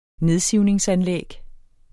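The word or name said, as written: nedsivningsanlæg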